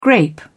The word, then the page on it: grape: British English